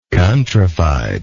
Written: countrified

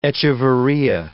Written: echeveria

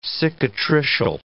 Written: cicatricial

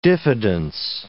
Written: diffidence

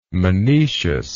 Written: monecious

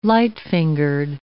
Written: light-fingered

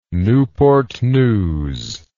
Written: newport news

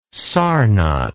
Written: sarnath

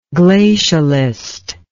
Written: glacialist

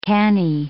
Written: canny

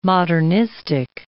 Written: modernistic

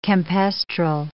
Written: campestral

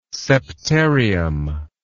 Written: septarium

